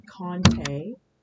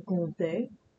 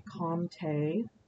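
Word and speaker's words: Comté: So there are people who come into my cheese counter looking for Conte or Conti.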